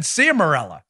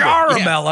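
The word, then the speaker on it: Ciaramella: Now, it turns out Charamella, who I had pronounced his name wrong, too. I had said Ciamarella. – Yeah, I remember. – Charamella. – Yeah, that's right. Yeah.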